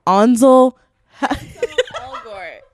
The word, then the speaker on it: Ansel Elgort: No, Ansel Elgort.